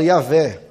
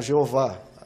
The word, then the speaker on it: Yahvé: É, a maioria fala Yahvé, alguns falam Jeová, Jeová é quase certo que não é essa pronúncia exata. A mais próxima que a gente tem é a ideia é Yahvé.